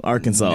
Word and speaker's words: Arkansas: Oh fuck, I said it wrong. Arkansas. – Yeah, you got Arkansas. – It right. – You said it right. – No, it's Arkansas.